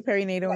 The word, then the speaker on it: perinatal: Am I saying that right? – No, you said it right. Perinatal anxiety.